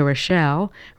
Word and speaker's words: Rochelle: And now for those of you who have asked for the correct spelling of my name, Rita Rochelle. Rita is R-I-T-A, and Rochelle is spelled R-O-C-H-E-L-L-E.